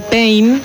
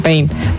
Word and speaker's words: Payne: El tema es que el apellido Payne, ¿sí? Como se escribe Payne, coincide con la palabra pain en inglés, que se escribe P-A-I-N, que es dolor, ¿sí?